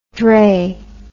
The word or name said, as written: drey